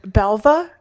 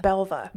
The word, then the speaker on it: Belva: Either way, Belva? – Yeah. – Belva. – Belva. B-E-L-V-A.